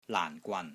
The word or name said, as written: LAAN4 GWAN3